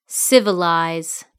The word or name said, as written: civilize